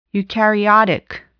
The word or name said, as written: eukaryotic